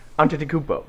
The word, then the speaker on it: Antetokounmpo: That one you just say it fast. Like you look at it like, and then you just go, Antetokounmpo.